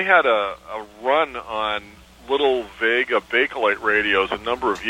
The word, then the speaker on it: Wega: It's actually spelled W-E-G-A, and it's pronounced Vega.